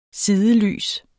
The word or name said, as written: sidelys